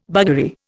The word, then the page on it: buggery: Indian